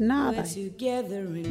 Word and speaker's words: nhaathayi: Nādai is spelt NHAATHAYI.